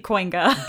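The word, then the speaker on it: Kooyenga: It's spelled Coyenga or Kuyenga. Mm-hmm. We hear it pronounced either of those ways, rarely Coinga. – Doesn't he say like it's like a coin or something? – Yeah, exactly. Exactly. But yeah, you very, very rarely hear people pronounce that correctly.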